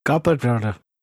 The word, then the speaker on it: Gyarados: Even though Y is technically the same vowel as I, it's generally not a soft vowel. So it's Gyarados. – It's like a, hero. – It's Gyarados.